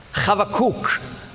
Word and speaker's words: Habakuk: Habakuk, so spricht man das auf Hebräisch aus, ist ein so schöner Name.